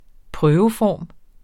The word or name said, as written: prøveform